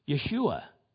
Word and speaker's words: Yeshua: Yeshua is the Hebrew way of pronouncing Joshua.